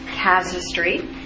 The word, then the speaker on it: casuistry: That's how it's pronounced, casuistry.